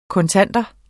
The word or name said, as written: kontanter